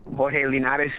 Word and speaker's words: Jorge Linares: Jorge Linares is the correct way to say his name.